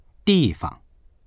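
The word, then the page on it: di4fang: Mandarin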